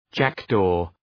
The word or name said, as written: jackdaw